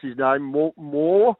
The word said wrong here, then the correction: Moir: Moir. M M Moyer Moyer M O I R Ashton, another bottom major.